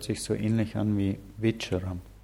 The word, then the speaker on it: вечером: Der Unterschied ist, das Wort вечером wird am Anfang deutlich mit W ausgesprochen, weil nach dem W im Russischen ein, Loka, äh, ein Vokal folgt.